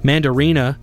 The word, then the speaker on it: Mandarina: Mandarina is the correct way to say it.